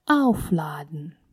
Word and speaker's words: aufladen: Aufladen. Aufladen. Aufladen. Заряжать. В этом глаголе приставка отделяемая, поэтому ударение будет именно на нее.